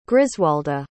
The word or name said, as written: griswalda